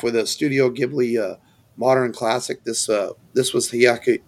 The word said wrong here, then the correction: Miyazaki: Miyazaki's follow-up to Spirited Away.